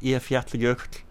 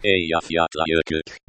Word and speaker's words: Eyjafjallajökull: Eyjafjallajökull. – Okay, do that again. – Eyjafjallajökull.